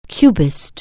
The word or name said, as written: Cubist